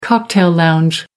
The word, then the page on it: cocktail lounge: American English